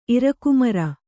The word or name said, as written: ಇರಕುಮರ